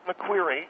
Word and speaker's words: McQueary: You did pronounce it right, McQueary, which is exactly what he is, not queer as in homosexual. A queer bird.